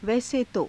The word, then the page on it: vaise to: Hindi